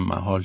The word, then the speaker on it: محال: هموطنان جان شما این نقمگی با عنوان زیبای حال محال منم تلفظ میکنم محال چون جناب آقای عقیلی عزیزمون محال خوندن